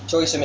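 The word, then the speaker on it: choi sum: Toy sim is spelled C-H-O-I, second word S-U-M. Toy sim.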